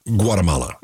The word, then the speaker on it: Guatemala: Consider this: suppose that you are somebody and you are in Guatemala. Am I saying that correctly? Am I supposed to say Guatemala? Guatemala's fine.